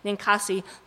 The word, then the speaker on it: Ninkasi: Beer, which is part of a dedication to Ninkasi. I'm not going to pronounce it incorrectly. Ninkasi. Ninkasi, the Sumerian goddess of brewing.